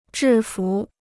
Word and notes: 制服: zhì fú

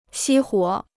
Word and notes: 熄火: xī huǒ

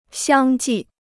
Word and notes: 相继: xiāng jì

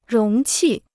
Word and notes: 容器: róng qì

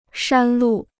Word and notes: 山路: shān lù

